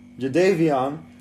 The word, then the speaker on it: Jadeveon: Jadeveon. J-A-D-E-V-E-O-N.